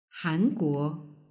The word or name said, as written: hanguo